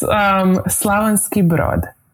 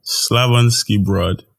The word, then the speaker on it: Slavonski Brod: Before we keep going, teach me how to say it the right way. I want to come correct. – It's Slavonski Brod. – Slavonski Brod.